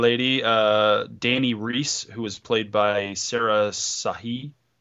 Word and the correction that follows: Shahi: I think I'm saying her name wrong, Shahi.